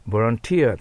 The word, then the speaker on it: volunteer: ただし、 volunteer となります、アクセント。